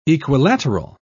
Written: equilateral

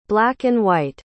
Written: black-and-white

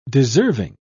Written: deserving

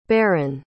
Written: barron

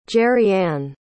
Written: gerianne

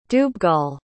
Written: dubhghall

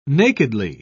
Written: nakedly

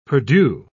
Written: perdu

